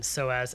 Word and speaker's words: psoas: Yeah, by the way, it's spelled p-s-o-a-s, and SOAS actually is the full is like SOAS ellipse.